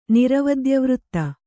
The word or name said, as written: ನಿರವದ್ಯವೃತ್ತ